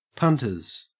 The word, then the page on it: punters: Australian English